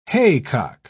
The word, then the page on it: haycock: British English